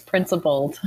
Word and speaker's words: Principled: Do you pronounce it Principled or Principal Ed? Let's hear that piece. – Man, you got it right on the first try. Nice work. It's Principled.